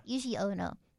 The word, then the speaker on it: Yuji Ono: Yes, by Yuji Ono. Or Yugi Ono. – Yugi Ono? – I don't know. Does Y-U-J-I? – Yes, Yuji. – Okay, Yuji. Yes. Yeah. Yuji Ono.